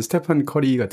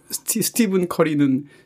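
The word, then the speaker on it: Stephen Curry: 최근에 무슨 그 NBA 농구 황제로 예전에 마이클 조던급이라고 얘기하는 스테판 커리 같은 경우도 네. 스티븐 커리거든요. 그게 스티븐 킹 할 때 하고 발음 스펠링이 똑같아요. – 그러네요. – 근데 스테판 킹이 아니고 스티븐 킹이라고 하면서 왜 스티븐 커리는 스테판 커리라고 하는지.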